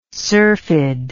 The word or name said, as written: syrphid